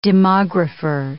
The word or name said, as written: demographer